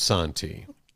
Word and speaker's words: Santhi: I should spell that S-A-N-T-H-I, but pronounced Santi.